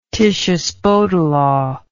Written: Titius-Bode law

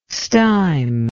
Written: stime

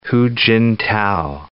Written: Hu Jintao

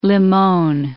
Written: Limon

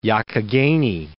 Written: Youghiogheny

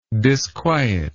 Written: disquietedness

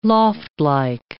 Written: loftlike

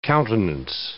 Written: countenance

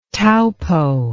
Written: Taupo

